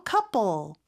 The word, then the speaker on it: couple: 커플 같은 경우는요. 그냥 커가 아니에요. 커 이런 발음이고 pull 이렇게 L 발음 정확하게 해줘야 되겠죠? 근데 만약에 P 발음이 조금 어렵다 그러면 쌍비읍 발음으로 couple 이렇게 발음해도 원어민들처럼 발음하는 거예요.